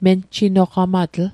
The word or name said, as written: menjinoqamatl